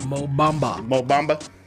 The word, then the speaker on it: Mo Bamba: Mo Bamba, Big Mo.